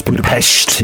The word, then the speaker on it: Budapest: Well, most people say Budapest, you know, but it's Budapest. Exactly. – We have to say what the locals say.